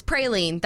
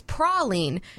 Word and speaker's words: praline: Game's because I just was on Southern Living's website, okay, and they said one is praline. That's a favorite pronunciation, like you just heard her say, in mm-hmm. Georgia and the surrounding states. The other is praline. Mm-hmm. That's what you're most likely to hear in Louisiana and around the Gulf Coast.